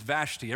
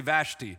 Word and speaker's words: Vashti: I got Logos, and I realized that I was pronouncing her name wrong. I haven't got to the other names, but I got to hers. Her name's Vashti. Everybody say Vashti. Vashti. There you go. That's her name.